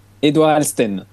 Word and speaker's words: Édouard Eltsen: J'espère bien prononcer votre nom. Est-ce que vous m'entendez bien? – Très bien. Bon, vous m'entendez également? – Oui, on vous entend. Alors, Édouard, prononcez-le à ma place. Comme ça, les auditeurs le retiendront mieux et bien.